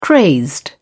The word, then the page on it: crazed: British English